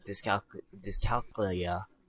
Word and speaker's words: dyscalculia: But for RTSs, I think just if a person with a dyscalculia, I don't even know. – Dyscalculia. Oh, I've been pronouncing it wrong, too.